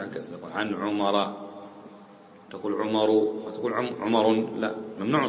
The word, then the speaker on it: عمرو: هذه واو فقط حق داود الله يرضى عليه داود عطاهية ما تلفظ هذه فقط ليميز بها عمرو عن عمر.